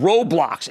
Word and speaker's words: Roblox: And it's called Roblox. And you pronounce the O like O.